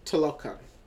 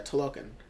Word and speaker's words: Talokan: It is uh, Talokan. Wait, let me see. Yeah, that's about right. – Yeah, Talokan.